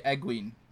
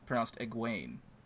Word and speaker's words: Egwene: But you also say Egwene when it's pronounced Egwene.